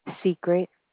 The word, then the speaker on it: Secret: Secret. – Can you spell that? – S-E-C-R-E-T.